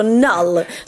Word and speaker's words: Knull: Han heter Knull, eller då Null som det uttalas på engelska.